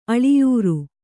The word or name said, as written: ಅಳಿಯೂರು